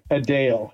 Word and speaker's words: Adele: There's certain things I can sing, but you know, it's not like I'm not like Adele. That's how you pronounce her name, Adele. Everyone pronounced it wrong.